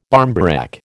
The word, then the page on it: barmbrack: American English